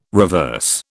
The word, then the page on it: reverse: British English